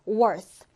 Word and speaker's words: worth: 이 단어 th 발음 주의해서 읽어보면요. worth.